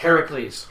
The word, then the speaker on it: Heracles: But if you called it, how was it pronounced? – Heracles. – Heracles. People wouldn't know what it is. They'd see it and they'd go, "Oh, Hercules," and they'd be like, "No, it's Heracles," and they'd be like, "Oh, it's not the same thing."